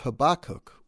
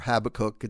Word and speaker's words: Habakkuk: The first reading comes from the Old Testament from the book of Habakkuk or Habakkuk. It's the prophet is pronounced different ways by different people.